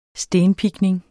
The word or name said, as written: stenpikning